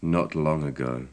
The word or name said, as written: not long ago